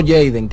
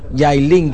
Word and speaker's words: Jalen: No Jaden, que Jaden es el segundo jugador, sino Jalen, como su pronunciación sería, Jalen Williams.